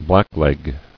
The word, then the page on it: Blackleg: black·leg